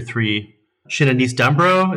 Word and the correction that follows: Dambrot: is that, am I saying that right? – Shinanise Dambrot. – Brot, sorry.